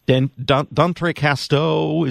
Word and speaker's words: D'entrecasteaux: Yeah, so you actually didn't butcher it, so good job with that. But anyway, so I would have. – D'entrecasteaux, it's like it's an archipelago, I think, near the New Guinea island.